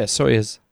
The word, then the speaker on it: Soyuz: Yeah, Soyuz. – Soyuz? – Yeah. – So it's, I found on the internet, it's $80 million per seat to send Americans up. – Pretty steep. – On the Soyuz.